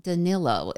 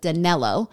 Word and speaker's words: Danilo: Kate, first, a big thank you to my Latin America listeners who said his name is most likely pronounced Danilo instead of Danello, like I was saying and like mainstream media is saying.